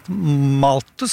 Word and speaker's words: Malthus: Nå står Ola Innseth her, han har skrevet roman fra Moss, men han har faktisk lest Malthus, som du uttalte riktig, tror jeg det er.